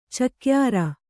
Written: ಚಕ್ಯಾರ

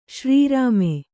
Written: ಶ್ರೀರಾಮೆ

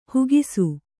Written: ಹಾಕಿಸು

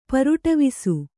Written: ಪರುಟವಿಸು

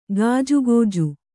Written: ಗೊಜಗೊಜ

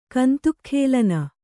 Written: ಕಂತುಕಖೇಲನ

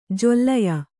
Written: ಜೊಲ್ಲೆಯ

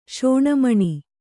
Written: ಶೋಣಮಣಿ

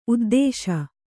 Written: ಉದ್ದೇಶ

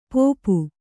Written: ಪೋಪು

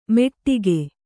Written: ಮೆಟ್ಟಿಕೆ

